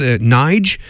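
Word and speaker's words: Nydge: We finished up there with new music from an artist, I guess, Nige. Would I pronounce it? It's short for Nigel. It's N-Y-D-G-E. He spells it that way.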